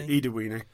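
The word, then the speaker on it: Ida Wenøe: She said that's how you say it. – Okay, Ida Weenie.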